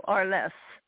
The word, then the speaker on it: Arles: And is it pronounced A-R-L-E-S for me? – Arles. It's a French name that coincidentally is the name of the father or one of the founders of the company. – I see. So Arles.